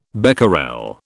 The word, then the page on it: becquerel: British English